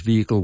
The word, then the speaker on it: vehicle: Their preferred pronunciation is vehicle without the H.